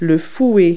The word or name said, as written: whisk